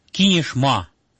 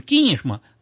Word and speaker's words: Кинешма: В первом слоге на «ки», не на «ма». Регион наш такой депрессивный, в нем разруха и бедность царят. Впрочем, Кинешма – город красивый, Кинешма у нас не говорят.